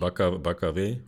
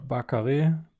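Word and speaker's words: Barcarès: Und dann haben wir tatsächlich auch noch GKA im April in, oh Gott, ich werde es falsch aussprechen, äh, Baccares, ist richtig? Frankreich? – Baccaré oder so? – Ba ja. – Baccaré, Baccaré, ja, ja, ich bin, bin so ein, ich bin Lateinkind.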